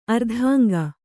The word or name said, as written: ಅರ್ಧಾಂಗ